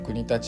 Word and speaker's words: 国立: あの国立っていう町はあの国立って書いてですね国あの国が立つって書いてあの国立って読むんですけど、